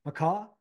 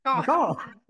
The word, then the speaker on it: Macau: And like you said, he's playing this week on the Asian Tours International Series Macau. I think I pronounced that right. Macau. – Macau.